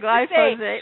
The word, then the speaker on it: glyphosate: She was asking, are the fungus becoming stronger because those that are able to, able to withstand the glyphosate. I can't pronounce it. – Glyphosate. I knew you'd glyphosate. – Have trouble with it. Everyone has trouble with that word. Glyphosate.